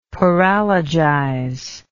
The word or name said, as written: paralogize